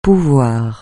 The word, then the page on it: pouvoir: francais